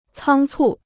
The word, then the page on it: 仓促: cāng cù